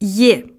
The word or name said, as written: ye